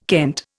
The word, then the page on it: kent: Indian